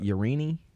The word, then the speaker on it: Yareni: Yareni? Y-A-R-E-N-I.